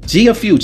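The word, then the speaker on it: Gia Fu: Gia Few. G I A F. No pun intended. F-U. That's how you pronounce her name.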